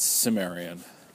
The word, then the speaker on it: Cimmerian: Like he's, you know, Conan the, I, I believe it's the, I always, I always said uh, Cimmerian, but I think it's pronounced Cimmerian, and it's like C I M M E R, and then M I A N.